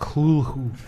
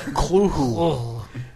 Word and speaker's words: Cthulhu: I believe it's pronounced Cluhu. – What? – Cluhu.